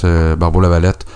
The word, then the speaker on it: Barbeau-Lavalette: Je trouve que Anaïs Barbeau-Lavalette je l'ai très mal prononcé. Non, non, Barbeau Lavalette.